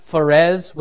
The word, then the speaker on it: Phares: Or some of y'all who like Greek and maybe the Septuagint and studying, it's actually pronounced Phares with kind of like an H, Phares.